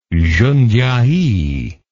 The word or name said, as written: Jundiai